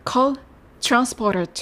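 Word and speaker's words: cotransporter: はい、それから 2 つ目、ートランスポーターなんですけれども、トランスのところをチュランスというように発音してみてください。コートランスポーター 2。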